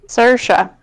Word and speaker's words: Saoirse: Saoirse. Like inertia.